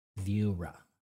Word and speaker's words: Viura: And there they will call it Viura.